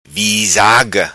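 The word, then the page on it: Visaga: patua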